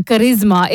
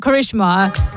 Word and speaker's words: Karisma: If you ever run into the Kapoor family, remember her name is a Karisma. It's not Karishma. Udit Narayan ji is saying it wrong there. And we've all been saying Karishma for all these years, but it's not. It's Karisma.